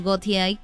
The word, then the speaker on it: Gotye: Y Metro, ustedes se han preguntado qué ha sido de Gotye, o Gotye, como se pronuncia realmente, este gran cantante que nos dio un super éxito.